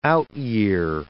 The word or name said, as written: out-year